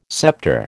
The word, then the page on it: sceptre: American English